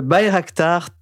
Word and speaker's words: Bayraktar: Le Bayraktar TB2.